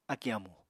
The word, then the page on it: akì amù: Apatani